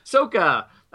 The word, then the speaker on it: Sokka: Sokka.